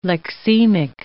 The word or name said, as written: lexemic